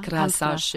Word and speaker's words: Krása: Krása. – J'ai toujours peur de dire... de faire une énorme faute de prononciation, Hans Krása.